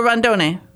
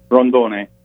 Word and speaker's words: Rondone: Is it pronounced Rondone or Rondone? – Rondone. – Rondone. That's what I thought.